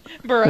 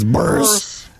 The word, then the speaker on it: Buress: Buress. – I think it's Hannibal. Buress. – Buress. It's Buress.